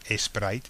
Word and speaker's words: Sprite: Sprite is Sprite, obviously, but the Spanish pronounce it Sprite, okay, sometimes without the T.